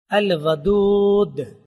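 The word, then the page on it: اَلْوَدُود: al-wadud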